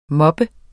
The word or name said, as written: moppe